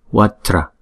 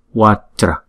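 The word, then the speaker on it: vajra: Chữ này là hai âm. Âm thứ nhất là Wa, âm thứ nhì là Tra. Chữ J này chúng ta đọc là Ja, Tra, Watra, Watra. Chữ này chúng ta đừng có đọc là qua chờ ra, là thành ba âm.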